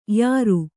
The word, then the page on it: ಯಾರು: kannada